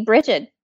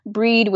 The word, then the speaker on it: Brigid: There are a variety of pronunciations and spellings. I prefer B as in bump, R, I, G as in ghost, I, D as in Dan. That's okay. How I typically spell it if I'm referring to her. But there's also Breed, which is spelled like the word bride, Bridget with a T at the end. And I have not heard of any incorrect pronunciations, unless you're saying like Brigid or something like that, but Brigid, typically you can't go wrong.